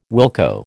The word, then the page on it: wilco: American English